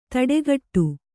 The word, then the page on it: ಥಟ್ಟುಗೆಡೆ: thaṭṭugeḍe